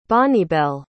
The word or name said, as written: Bonnibelle